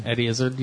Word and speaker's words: Eddie Izzard: Eddie Izzard, how do you say his name? – Eddie Izzard, yeah.